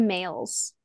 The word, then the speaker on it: Mayles: Deanna Mayles. – Mayles, like male, but it's spelled with a Y.